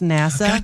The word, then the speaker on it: Nassau: But, if my next vacation is to go to NASA, Bahamas, right away I was saying Nassau, because I didn't know it was pronounced NASA.